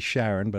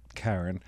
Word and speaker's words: Charon: Charon, it does look like the way it's spelt that it should be Charon, but it's Charon.